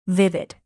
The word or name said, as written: vivid